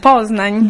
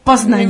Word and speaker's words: Poznań: Poznań. – Dov'è? – Poznań. – Poznań.